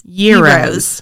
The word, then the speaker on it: gyro: It's like the G is silent. Yero. – Y-E-E-R-O. – Yero. – Yero. – Which is how I... – So, it rhymes with hero.